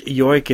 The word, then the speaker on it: joik: Next up, we have a form of music called Yoiking, or J-O-I-K is how it's spelled. It's a traditional form of song in Sami music, performed in Nordic countries and parts of Russia as well. Each yoik